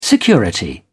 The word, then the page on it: security: British English